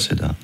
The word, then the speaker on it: Cedar: On prononce comme ça, hein? C'est ça. – Cedar, d'accord.